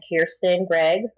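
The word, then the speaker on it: Keirsten: Name is, again, Kirsten Greggs.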